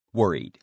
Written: worried

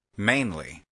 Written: mainly

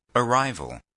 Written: arrival

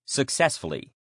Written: successfully

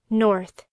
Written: north

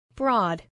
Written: broad

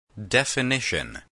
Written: definition

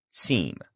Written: seem

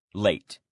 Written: late

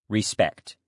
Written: respect

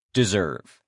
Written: deserve

